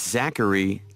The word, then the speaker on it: Zachary: No, it's spelled S-O-P-H-I-A. Oh, excuse me. S-O-P-H-I-A. That's right. – 4. – Your name, please? – It's Zachary Tanaka. – Is your first name Z-A-C-K-A-R-Y? – No, it's Z-A-C-H-A-R-Y.